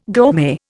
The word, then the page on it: gourmet: Indian